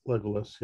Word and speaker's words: Legolas: Sam, Frodo, Legolas, is that how you say his name? – Yeah, Legolas, yeah.